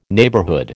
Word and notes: neighbourhood: American English

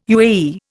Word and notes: UAE: Indian

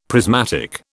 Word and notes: prismatic: British English

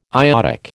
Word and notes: iodic: American English

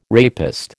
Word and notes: rapist: American English

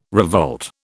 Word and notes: revolt: British English

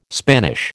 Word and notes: Spanish: American English

